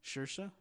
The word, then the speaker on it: Saoirse: Saoirse. – Saoirse? – Yeah.